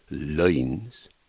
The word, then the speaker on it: loins: But with loins pronounced the same as lines, loins, there's a pun here which is usually missed.